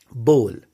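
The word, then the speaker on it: bowl: बोल, बोल। ठीक है, इसको अगर हम इजी वे में लेंगे तो बी ओ एल, बोल। तो बाउल नहीं बोलेंगे, बोल बोलेंगे। ठीक है, एक और प्रोनाउंसिएशन होता है जिसको मैंने अक्सर देखा है लोग गलत बोलते हैं।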